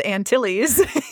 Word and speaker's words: Antilles: Speaking of utilities, It almost rhymes with Antilles.